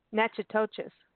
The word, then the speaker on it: Natchitoches: I'm going to spell it because it's not how it sounds. It's Natchitoches, like Natchitoches. Natchitoches is how you pronounce it.